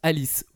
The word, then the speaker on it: Arisu: Mais Arisu, A-R-I-S-U, en, japonais, pardon, se prononce Alice, voire Alice C.